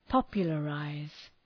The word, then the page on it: popularize: ‘pɒpjələ,raız